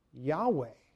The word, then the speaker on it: Yahweh: It's often pronounced Yahweh.